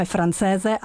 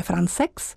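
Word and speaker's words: Françaix: Žána, jak my zde říkáme, francéze, ale byla jsem poučena mým kolegou z Lucemburska, právě Karlo Jansem, který v ní hraje, že se to vyslovuje Francex.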